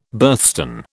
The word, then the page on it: birthstone: British English